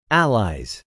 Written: allies

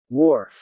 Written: wharf

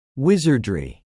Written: Wizardry

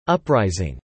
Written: uprising

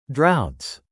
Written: droughts

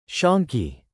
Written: shonky

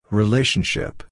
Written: relationship

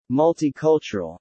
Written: Multicultural

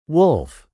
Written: wolf

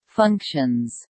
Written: functions

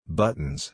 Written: buttons

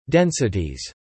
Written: densities

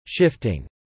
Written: shifting

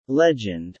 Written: legend

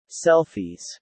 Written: Selfies

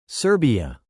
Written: Serbia

